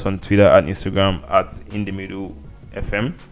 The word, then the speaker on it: InTheMiddleFM: Did I say it correctly this time around? – I think you did. – I did not say In The Middle FM like when you said I did. – Yeah, you said it correctly.